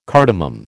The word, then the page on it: cardamom: American English